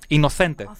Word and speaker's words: Innocente: Innocente. Innocente. Ε, προφέρουμε με θε, γιατί είναι μια ισπανική λέξη. Όταν μιλάμε στην Ισπανία, βάζουμε θε. – Μάλιστα. Όταν είμαστε στη Λατινική Αμερική, βάζουμε σε.